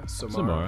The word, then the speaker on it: Samara: Is it Samara Wells? S-A-M-A-R-A. – Samara. – Samara. – I'd say Samara.